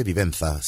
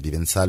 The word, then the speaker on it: Vivenza: Se escribe Vivenza, sí, pero Vivenza lo decimos porque como es francés seguramente lo pronuncian así allí.